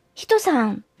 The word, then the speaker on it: 1103: えっと、数字で 1103 と書いて、ヒトさんと読みます。